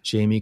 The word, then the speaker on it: Jamie: Jamie Corroon, J A M I E.